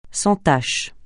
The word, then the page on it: sans tache: francais